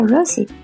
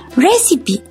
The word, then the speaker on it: receipt: Entonces a un amigo que entendía español le pregunté cómo se pronunciaba receipt. Él me ayudó y me dijo que gritara recipe. Yo hice lo que me dijo con mucho orgullo. Para mi sorpresa, todos se morían de la risa, hasta la maestra. En mi vida he vuelto a pronunciar esa palabra mal y no quiero saber de ella.